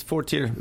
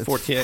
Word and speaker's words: Fortier: It's Fortier. – Fortier. – Yeah. – Okay. – That's the English pronunciation. Otherwise, it's Fortier.